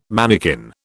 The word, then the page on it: manikin: British English